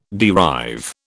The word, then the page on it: derive: British English